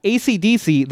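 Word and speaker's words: Esidisi: We have ACDC, who is just pronounced ACDC. They just spell it differently. They spell it phonetically.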